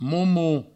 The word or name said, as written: momo